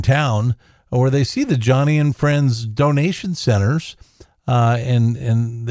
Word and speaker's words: Joni: J-O-N-I. – J-O-N-I. By looks the way. Like Joni, but yeah. It's pronounced Johnny. Yep. Her dad wanted a boy. That's the story she tells. So it is Johnny, but J-O-N-I.